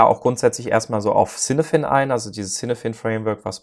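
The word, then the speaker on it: Cynefin: Ähm, wo ich übrigens diese Woche gelernt habe, dass das gaelisch ausgesprochen wurde, irgendwie so Kinaiwin ist.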